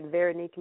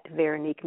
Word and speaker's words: Veronique: You can find me on Facebook at Veronique Music, and music is spelled with the Q. My name is spelled V as in Victor, E-R-O-N, like Nancy, I-Q-U-E.